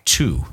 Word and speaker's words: Thy: The area is called Two. It's pronounced THY, but it's pronounced Two."